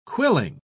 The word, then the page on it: quilling: British English